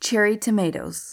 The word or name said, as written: cherry tomatoes